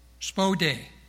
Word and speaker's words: spoude: The Greek word is spode, and I'll spell it for you just so you know. S-P-O-U-D-E, spode.